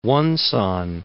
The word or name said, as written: Wonsan